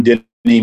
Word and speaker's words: Dennie: Or go to my Facebook page, John Denny Music.